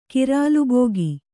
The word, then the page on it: ಗರಲಭುಕ್ಕು: Kannada